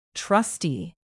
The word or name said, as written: trustee